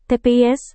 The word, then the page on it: tps: Indonesian